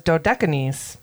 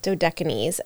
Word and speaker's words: Dodecanese: My computer says Dodecanese. – Dodecanese.